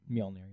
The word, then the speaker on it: Mjolnir: That's how you say it, right? – Mjolnir, yeah.